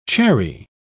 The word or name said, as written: cherry